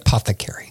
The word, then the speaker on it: apothecary: Apothecary.